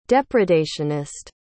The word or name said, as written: depredationist